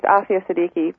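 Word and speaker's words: Aafia Siddiqui: Well, you did mangle her name a little bit. It's Aifa Siddiqui, but you're very close.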